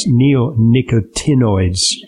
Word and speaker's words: neonicotinoids: And in fact, European countries have limited the use of certain neonicotinamides. I'm mispronouncing it. Neonicotinoids.